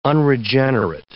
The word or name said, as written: unregenerate